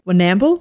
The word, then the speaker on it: Warrnambool: But her injuries were so bad that she was transferred to Warrnambool. Am I saying that right? W-A-R-R-N-A-M-B-O-O-L. – Yeah, Warrnambool.